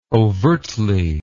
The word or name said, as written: overtly